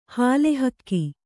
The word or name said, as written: ಹಾಲುಹಕ್ಕಿ